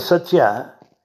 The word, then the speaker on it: satya: Therefore, it is very clear to all of us, the word satya has 3 syllables. Satya, sa, ta, ya.